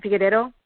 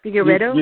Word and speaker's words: Figueiredo: How do you say Figueiredo, right? Figueiredo? – Yeah. – Figueiredo? – You got it right.